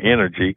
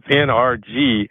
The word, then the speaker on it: NRG: Where people mispronounce it and say energy. – Energy. – But it was NRG.